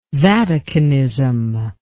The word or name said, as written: Vaticanism